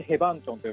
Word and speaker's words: 解放村: 方もいらっしゃるかなと思うんですけどヘバンチョンというエリアに来てますヘバンチョンセバンチョンってですね漢字で書くと解放村って書くんです、ね、解放館とかの解放に村と書いてヘバンチョンと読むんですけ